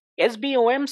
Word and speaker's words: SBOM: government, you need to have something called an SBOM, S-B-O-M. SBOM stands for, and it's pronounced SBOM,